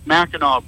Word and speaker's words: Mackinac: The spelling's all Indian and everything, but it's pronounced Mackinac Bridge.